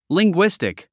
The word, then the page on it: linguistic: liŋɡwístik; リングィスティック